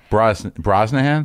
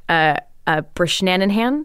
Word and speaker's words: Brosnahan: Brishnanahan. – Come on. – Brosnahan. – Brosnahan. – Brisnanan.